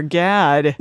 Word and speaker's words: Gad: I was like, hey, is it Gad? Did I pronounce that right? And he's like, yeah, a lot of people get it wrong and think it's pronounced. – What did they say? – Well, they think it's like God or something like that.